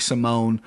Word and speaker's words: Simon: Montel he's 0-1 in the UFC, coming off the Contender Series, lost to Ricky Simone. I hate that I'm pronouncing Simone. That's clearly Simon.